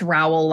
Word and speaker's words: Rowell: And so for those listening, my first name, Lainey, is L-A-I-N-I-E. And it's Rowell, like Powell, R-O-W-E-L-L.